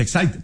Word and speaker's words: Exide: se escribe Exide, se pronuncia Exide, decía la, el comercial de las baterías.